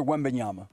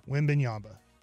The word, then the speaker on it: Wembanyama: and I know I say his name wrong, but. – Say it. – Victor Wembanyama. – Wembanyama. Did I say it right that time? Or I got really. – You got it. That was pretty good, dude.